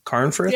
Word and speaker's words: Carnforth: Did I say that right, Ivan? Carnforth? – Yeah.